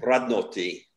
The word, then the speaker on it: Radnoti: Radnoti. You got to roll the R.